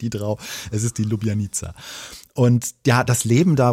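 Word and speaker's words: Ljubljanica: Das ist die Lubjanica, wie ich gelernt habe, so spricht man das aus.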